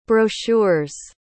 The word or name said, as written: brochures